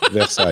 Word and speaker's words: Versailles: Versailles.